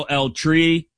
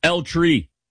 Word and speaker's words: El Tri: where I know El Tri. I said that as white as possible. El Tri? That's not a place where you roll your R. El Tri.